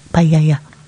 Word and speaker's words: paella: I think it was paella. Paella. Paella. That's how you pronounce it.